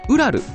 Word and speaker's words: 霧: ちなみに、本作なんですけど、ウラルと読むんですけども、音声だけだと分かりづらいかもしれませんけど、霧と書いてですね、ウラルと読みます。